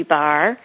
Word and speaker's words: barre: It's called Booty Bar, and bar is spelled B-A-R-R-E. Sometimes we think of bar as just that B-A-R, but we actually use a ballet bar in the studio for this class.